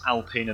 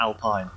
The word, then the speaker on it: Alpine: I have to keep remembering it's Alpine and not Alpine.